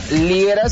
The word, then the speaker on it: Liera: Me encuentras en todas las redes sociales por mi nombre, Fernando Liera, se escribe L-I-E-R-A.